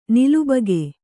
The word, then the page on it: ನಿಲಬಾಕಿ: nilabāki